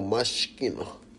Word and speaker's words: Moschino: Oh, Moschino. – Really, I right, too, but Moschino.